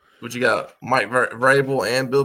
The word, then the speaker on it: Vrabel: Vrabel.